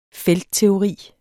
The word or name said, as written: feltteori